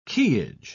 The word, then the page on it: quayage: British English